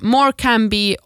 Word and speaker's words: Morecambe: Morecambe och Gloucestershire.